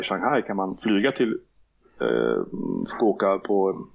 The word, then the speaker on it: Fukuoka: Vi fortsätter till Fukuoka, om jag uttalat det rätt. – Fukuoka. Ja.